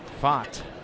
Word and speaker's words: Pfaadt: They got shut down by one of the Diamondbacks' pitching prospects who was making his AAA debut for Reno, right-hander Brandon Fott. Allowed one run on three hits in six innings. So here's a strike to O'Keefe. Fott, spelled P-F-A-A-D-T. Pronounced Fott, disappointingly.